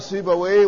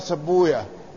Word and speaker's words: سيبويه: سيبويه صاحب كتابه اسمه الكتاب، يقال سيبويه ويقال سبوية، وسبوية هي لغة المحدثين، وأما لغة أهل اللغة استخدامهم يقولون في مثل هذه الأسماء يختمونها بويه، والمحدثون لا ينطقون ويه، لأن ويه عندهم بمعنى الشيطان، فهم ما يتعاملون مع الشيطان. ولذلك ما جاء على هذه الأوزان هكذا ينطقون سبويا ونفطويا وراهويا وغيرها.